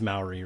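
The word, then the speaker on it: Māori: Right, I'm saying that correctly, right? I'm not saying like an idiot tonight, am I? It is Maori, right? – Yeah, I think that's correct.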